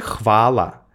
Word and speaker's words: хвала: это хвала или это хвала? Что, как? По-русски мы скажем хвала. И это будет иметь одно значение. По-сербски хвала. Это спасибо. Но нужно сказать хвала.